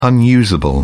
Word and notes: unusable: British English

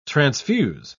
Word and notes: transfuse: British English

